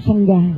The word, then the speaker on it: Sangha: Thì tương tự, cái chữ Sangha và Tăng già đó, thì họ không thể đọc là Sangha mà đọc là Sang.